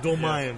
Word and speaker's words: Dolmayan: He's John Dolmayan. Did I say that right? – Dolmayan.